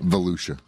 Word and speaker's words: Volusia: I'm not sure if that's the proper way to pronounce it. – Volusia. – Volusia, not Volusia. Volusia.